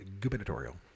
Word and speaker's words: gubernatorial: It's got a secret B in it. – Gubernatorial. – When you say it right. Gubernatorial.